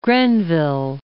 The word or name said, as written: Grenville